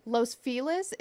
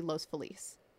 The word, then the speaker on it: Los Feliz: the neighborhood of Los Feliz is often referred to as Los Feliz. And that's just because we use our American accents when pronouncing Spanish words in California. – Wait, so which way does everyone say it? – Los Feliz is the way that we pronounce it in LA. The proper way to pronounce it would be Los Feliz.